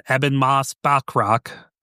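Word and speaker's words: Ebon Moss-Bachrach: And then Eben Moss Bachrock.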